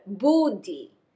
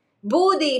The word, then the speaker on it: buddhi: Intellectual blasphemy is actually doing dishonor to our humanity and to our intellect, doing dishonor to our buddhi. Buddhi is spelled B-U-D-D-H-I. It's not about the butt. In this case, it's about our buddhi.